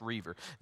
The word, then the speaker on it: Roever: Reaver is R-O-Ever, R-O-E-V-E-R, but it's pronounced Reaver.